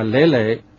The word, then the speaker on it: 累累: 总累累的累累，读音它是阳平，作为韵脚，累它是平声，才能跟前后的韵脚相压。